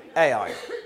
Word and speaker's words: Ai: It's the city that is spelt A-I. Ai.